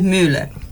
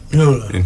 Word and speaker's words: Müller: Pero bueno, la pronunciación correcta más o menos es Müller. – Müller, claro. Müller. Tienes que poner la boca de U, pero decir boca Müller. De U y pronunciar la I.